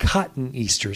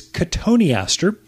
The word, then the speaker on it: cotoneaster: One's cotoneaster, or people call it cotton easter. It's kind of how it's spelled phonetically, but it's cotoneaster.